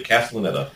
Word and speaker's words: Castellaneta: Where Dan Castellaneta, Castellaneta is that how you pronounce it? – Yep, Castellaneta, voice Cast- of Homer Simpson.